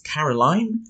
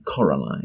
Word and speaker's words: Coraline: He mispronounces her name as Caroline. – Yep. – When it's actually Coraline.